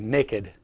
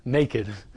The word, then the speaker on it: naked: but I would say naked. And they said, it's not naked, it's naked. And so I'm now very self-conscious about pronouncing it correctly. Naked, not naked.